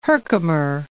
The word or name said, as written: Herkimer